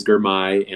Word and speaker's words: Girmay: It is Girmay, and last name is Zahalai. It rhymes with Girmay.